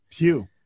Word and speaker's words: Pew: Yeah, I was happy to see that the PU, is it pronounced PU? PU Research Center? – Pew. – Oh, Pew.